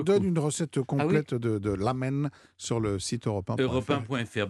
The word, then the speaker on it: ramen: Alors, à la japonaise, on ne prononce pas ramen, mais on prononce lamen.